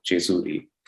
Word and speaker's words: Jejuri: I did pick up this like really thin volume of poems, and I'm not a reader of poetry, called Jejuri, or in Marathi, it's more closely would have been pronounced Jejuri.